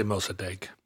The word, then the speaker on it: Mossadegh: Mossadegh, maybe? – Mm-hmm. Let's say Mossadegh. – Mossadegh seems better.